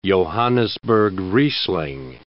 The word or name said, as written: Johannisberg Riesling